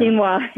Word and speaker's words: quinoa: And how do you say that? Quino? I always mispronounce that. – Quinoa. Yeah, quinoa.